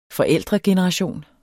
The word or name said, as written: forældregeneration